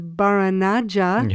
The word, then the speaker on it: Baranaja: the practice, I believe, if I've pronounce it correctly, is Baranaja. – Yeah, right.